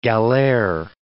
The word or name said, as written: galere